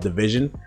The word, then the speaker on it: DVSN: They're called Division, and it's spelled DVSN if you want to search it.